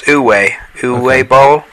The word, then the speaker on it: Uwe Boll: Yeah, it's pronounced Uwe Boll.